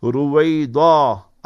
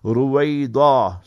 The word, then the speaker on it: Ruwaydha: No need to change, just pronounce it correctly. Ruwaida, R-U-W-A-Y-D-H-A. So that D-H-A will highlight the dwad. Ruwaida.